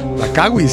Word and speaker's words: Langkawi: No, pero es la pronunciación, yo no sé si estaba bien. – La Langkawi. – Langkawi, sí.